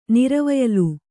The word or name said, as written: ನಿರ್ವಯಲು